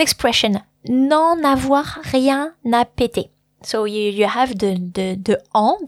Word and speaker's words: péter: Now, the verb péter, which is spelled a little bit like the name Peter, but with E acute on the first, so the first E has an accent aigu on it.